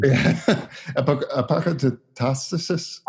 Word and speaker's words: Apokatastasis: Apokatastasis. Is that? – Yeah.